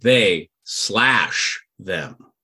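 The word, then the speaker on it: They/Them: It's actually supposed to be pronounced they slash them. – Oh, okay. – Slash. Because it's a slash.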